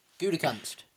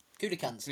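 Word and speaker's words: Gutekunst: I'm going to absolutely butcher it, but it's like Grukenverse or something like that. – Gudekunst. – Yeah, well, Gudekunst. – Yeah, that makes more sense.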